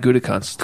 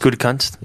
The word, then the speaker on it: Gutekunst: Is that how you pronounce it? – Brian Gutekunst. – Gutekunst. – Yeah.